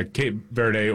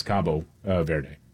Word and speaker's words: Cabo Verde: Yeah, Cape Verde, or in Portuguese, it's Cabo Verde.